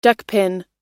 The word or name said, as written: duckpin